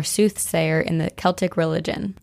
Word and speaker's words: Celtic: Celtic? – Yeah, Celtic.